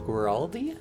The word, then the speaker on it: Guaraldi: I think it's pronounced Guaraldi. – Guaraldi, I thought.